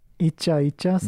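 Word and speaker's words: いちゃいちゃ: It's not, I'm going to say it wrong. You have to correct me. Ichae? – Icha icha. – That's the word.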